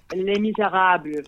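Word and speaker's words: Les Misérables: It's Les Miserables.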